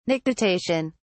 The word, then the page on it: nictitation: British English